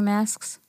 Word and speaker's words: masks: Aaliyah makes fun of me because she's like, why do you hit the little at the end? So I'm like, well, how do you say it? Masks. You have to.